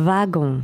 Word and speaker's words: wagon: The W in this word is pronounced like a V. – That's right, yeah. – So it's a wagon.